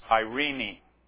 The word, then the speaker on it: eirene: I might not pronounce it right, but it's Irene. E-I-R-E-N-E.